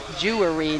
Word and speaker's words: Jewry: And in case I'm not pronouncing very well, that's Jewry, not jewelry.